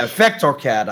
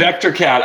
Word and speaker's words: Vectorcat: and I'm probably pronouncing it Vecrocat, we're sorry, putting you through some real hoops here. – There's a T in there. Vectorcat, I think. – Vectorcat.